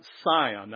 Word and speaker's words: Sion: "But you are come unto Mount Sion." Now there it's spelled with an S, but it's the same as Zion. "And unto the city of the living God."